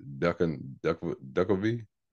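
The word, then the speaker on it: Duchovny: I'm about to butcher his last name, but it's Duckovny.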